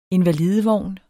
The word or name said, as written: INVALIDEVOGNE